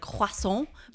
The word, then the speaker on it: croissant: I mean, I don't need to say it like croissant, but right. It's croissant. It's a French word.